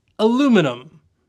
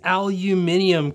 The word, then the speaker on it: aluminum: Aluminum. It's aluminum. It's spelled like aluminum. Where in the world did Johnny Ive and his aluminium crap come from? – Well, it's because it used to be spelt aluminium.